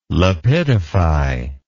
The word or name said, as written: lapidify